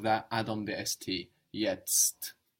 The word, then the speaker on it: Jetzt: Jetzt. – Good. You can think first of yet and then add your ST because this is a kind of complicated word.